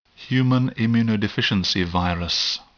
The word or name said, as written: human immunodeficiency virus